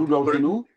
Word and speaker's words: Rudolfinum: Do I pronounce Rudolfinum? Very good.